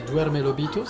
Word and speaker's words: Duerme Lobito: Euh, c'est un duo qui va nous interpréter un titre qui s'appelle Duerme Lobito. Je sais pas si je l'ai bien prononcé, mais Duerme Lobito, c'est ça C'est parfait.